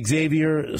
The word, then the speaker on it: Xavier: Andy Reid says Xavier. Stan Weber says Xavier, and he called games involving Texas, and the official Texas website on his player profile page that has a pronunciation leak says Xavier. So I'm going to defer to them.